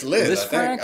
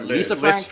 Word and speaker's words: Lisfranc: Do we pronounce it Lisfranc? – It's Lisfranc. I've always heard Lisfranc.